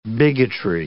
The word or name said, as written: bigotry